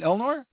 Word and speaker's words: Elnor: I think the biggest mistake they did from my perspective was getting rid of Elnor. That was a great character. I'm saying it right? Elnor? – Yep.